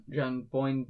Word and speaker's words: John Boyne: As someone who has read other John Boyne books, John John Boyne books.